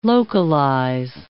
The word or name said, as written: localize